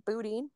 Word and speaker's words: Boudin: Did I say that right? Boudin? – Boudin? Yeah.